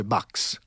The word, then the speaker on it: Bach: Si intitola After Bach, questa è la pronuncia italiana, poi lo sai tromba che in tedesco si dice Bax.